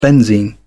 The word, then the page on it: benzene: British English